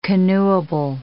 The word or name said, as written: canoeable